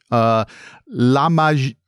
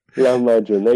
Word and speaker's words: Lahmacun: Is perfect. That? You're a natural in Turkish. That's how you say it. Lahmacun, exactly. The origin of the word actually comes from Arabic, lam abecin, meaning ground meat. In Turkish, we pronounce it as lahmacun, like you said.